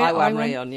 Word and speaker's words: Iwan Rheon: Sean Rhys-Williams, Iwan, is that how you say it? – Iwan Rheon, yeah.